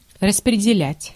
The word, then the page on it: share: American English; GenAm; /ʃɛɚ/